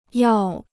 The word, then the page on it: 药: yào